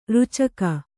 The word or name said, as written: ರುಚಕ